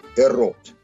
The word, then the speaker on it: эрот: В сократовском диалоге с Диотимой «эрос» произносится на греческий манер «эрот».